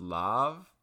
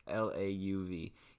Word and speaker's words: Lauv: Easy Love by, I think his name is pronounced Lav. It's Lauv.